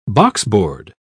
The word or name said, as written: boxboard